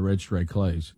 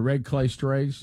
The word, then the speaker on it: Red Clay Strays: I will say that the red clay strays, am I saying it right? Because sometimes I call them the red stray clays. – Right. – Is it the red clay strays? – Correct. Correct. Red clay. The clay is red.